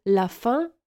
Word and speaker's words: la fin: Koniec, czyli la fin, wymawiamy tak samo jak głód. La fin. Pamiętasz wyrażenie j'ai faim? Czyli jestem głodny, dosłownie mam głód. Faim, la faim i la faim to są homonimy.